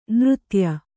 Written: ನೃತ್ಯ

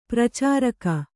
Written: ಪರಿಚಾರಕಿ

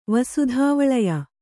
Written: ವಸುಧಾವಳಯ

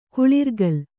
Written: ಕುಳಿರ್ಗಲ್

